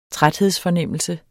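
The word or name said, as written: træthedsfornemmelse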